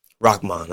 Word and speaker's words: Rahman: Rahman, do it again. – Rahman. That's what you say in Brooklyn. Rahman. – Rahman? Okay. I guess I'm obviously not from Brooklyn. – She probably said it right. Yeah, she's from Queens.